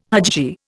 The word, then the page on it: hajji: Indian